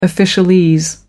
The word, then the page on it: officialese: American English